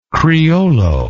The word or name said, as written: criollo